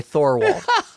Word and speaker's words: Thorwald: The choice of Raymond Burr for Lars Torvald. – Thanks for treating us all to the proper Nordic pronunciation. I mean, even in the movie, they say Thorwald.